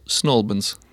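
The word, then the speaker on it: St Albans: Attenzione, Snowbans, due sillabe e basta. – Snowbans. – Snowbans. – Quindi si perdono via la T. – Sì, esatto. Sì, riducono al massimo perché l'accento va sulla prima sillaba di Albans e il resto viene ridotto.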